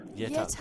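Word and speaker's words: Jette: Yetta.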